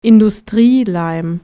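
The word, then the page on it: còla: Piedmontese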